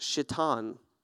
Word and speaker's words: Shittim: Every other translation, the name of the grove is S-H-I-T-T-I-M. It's pronounced Shittim. Shittim. They were living in Shittim, okay?